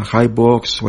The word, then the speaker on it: HiBooks: Ahora, usted le dice, no, es que a mí me lo pronuncia HiBooks. Bueno, acá le arreglé una configuración en el diccionario del VoiceOver que ya lo pronuncia bien como HiBooks.